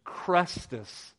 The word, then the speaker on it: Chrestus: He actually refers in his historical accounts to the constant riots of the Jews in Rome at the instigation of, and I'm going to pronounce this, Crestus, C-H-R-E-S-T-U-S.